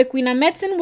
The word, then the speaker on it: Quinametzin: The next one I have is the Queenamitzen. Why did I say it like that? the Queen Queenamitzen. Now, the Quinametzin were a race of giants that lived in Mexico.